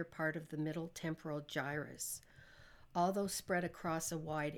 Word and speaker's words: gyrus: I don't know how to pronounce his case. G-Y-R-U-S. Gyrus? – Gyrus.